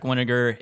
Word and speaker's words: Winegar: My Twitter handle is my name, Patrick Winegar. It is spelled W-I-N-E-G-A-R, like vinegar, but with a W.